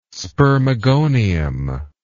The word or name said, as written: spermogonium